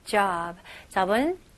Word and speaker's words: job: 여기서 j, j, 그 다음에 ob, job, okay? job. 진짜 어 발음, 여기 오 발음은 진짜 턱을 내리면서 넓게, 입을 넓게 해주셔야 됩니다.